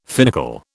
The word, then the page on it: finical: British English